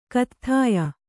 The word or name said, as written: ಕತ್ಥಾಯ